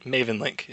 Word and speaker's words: Mavenlink: Mavenlink. Yep. – Mavenlink. Okay. Why do I keep always missing the N on it? I guess it just runs together.